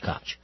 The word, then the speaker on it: Koch: Well, I say Koch because there was a mayor in New York, 25 years ago, Ed Koch was his name, so I it's the same, spelled the same way, so I'm saying Koch. – Couch? No? – Koch. – Okay.